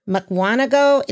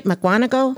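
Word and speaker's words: Mukwonago: Aiden Greif is a sixth grade doing ninth grade math and is a student at Norris Academy in Muckwanago. Did I say that right? Muckwanago? – Yes.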